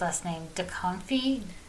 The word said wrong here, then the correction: Duchovny: Duchovny. Duchovny. Duchovny.